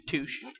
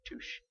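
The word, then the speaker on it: touché: Touche. Stop pronouncing the shay on the end of touche. – Oh, really? – Yeah. – You've dropped the shay.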